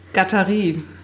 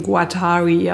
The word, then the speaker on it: Guattari: Die Amerikaner sprechen ihn Guattari aus. Aber er ist Franzose.